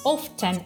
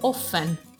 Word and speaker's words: often: Nel caso del present simple, per esempio, abbiamo always, che vuol dire sempre, sometimes, qualche volta, often o often. Entrambi le pronunce sono buone, che vuol dire spesso.